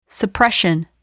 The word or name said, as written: suppression